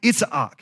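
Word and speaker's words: Yitzhak: In Hebrew, you actually separate all the vowels. You don't blend them together like that. So it's pronounced Itzhak.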